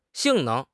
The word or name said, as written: 性能